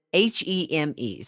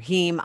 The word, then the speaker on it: heme: H-E-M-E. – I would say heme. – Okay, heme. – Like hemoglobin, but. – Yes. Okay, so how could the heme in the Impossible Burger be identical to the heme humans have been consuming for hundreds of thousands of years in meat and other foods if you genetically engineer it?